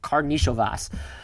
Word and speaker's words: Karnišovas: I tried to look this up phonetically beforehand. It is Karnišovas.